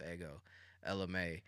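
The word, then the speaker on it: Ego Ella May: Yeah, so, it's pronounced Ego LMA. It's her name. Her first, it's spelled Ego, E-G-O, but it's pronounced Ego LMA, E-L-L-A-M-A-Y.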